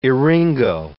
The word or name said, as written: eryngo